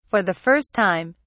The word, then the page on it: for the first time: British English